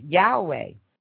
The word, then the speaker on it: Yahweh: The Hebrew pronunciation of his name is Yahweh, although in many English translations, beginning with William Teasdale, it is transliterated Jehovah.